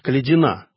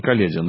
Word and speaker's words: Калядин: И вот первая книга – это «Убийство атамана Калядина». Действительно убийство. – Калядин все-таки, не Калядин, да? – Калядин, да, так правильнее, так сказать, иметь ударение.